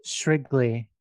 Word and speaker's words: Shrigley: This book here, like David Shirley? – Shrigley, yes.